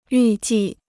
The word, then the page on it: 预计: yù jì